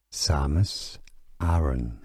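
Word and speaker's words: Samus Aran: Samus Samus Aron.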